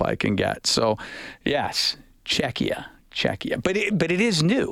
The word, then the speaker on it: Czechia: So, IMAC, yeah, Czechia. Yeah, tell Bob it's pronounced Czechia.